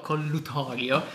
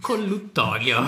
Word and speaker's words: collutorio: Vabbè, lasciamolo collutorio. – Collutorio. – Ma non diremo mai che questo podcast si chiama collutorio, pronunceremo colluttorio.